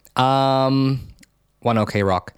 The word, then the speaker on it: ONE OK ROCK: I'm trying to pronounce it the way that my friend Dallin has told me to pronounce it. Puppets Can't Control You, Japanese version. One O Rock. One OK Rock.